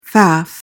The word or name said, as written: faff